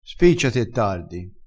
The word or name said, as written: spicciassi